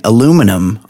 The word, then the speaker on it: aluminum: So, you know, both aluminium and aluminum are correct because the guy that invented or discovered aluminium, he named it aluminium. And then after it was named that, he changed the name to aluminum. So both pronunciations actually correct. Aluminium and aluminum.